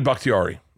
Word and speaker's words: Bakhtiari: Bakhtiari. – Yeah. – David Bakhtiari.